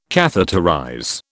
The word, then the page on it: catheterize: British English